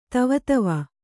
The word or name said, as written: ದೇವದೇವ